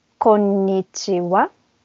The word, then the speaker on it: konnichiwa: En japonés la pronunciación es bastante plano, no tiene un acento fuerte. Entonces, hola es konnichiwa.